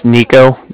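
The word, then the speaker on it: Niko: My name's Nico. That's N-I-K-O. And I'm calling with the Bank of Cardiff. And Cardiff is spelled C-A-R-D-I-F-F, as in Frank.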